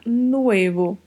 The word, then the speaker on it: nuevo: Muito cuidado ao pronunciar o V, porque o V no espanhol tem um leve som de B. Então vamos pronunciar nuevo, nuevo. E fechada, O fechado e esse V com leve som de B. Nuevo.